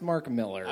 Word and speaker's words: Millar: Well, the beginning of this fight is it's pronounced Mark Miller.